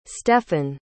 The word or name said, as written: stefan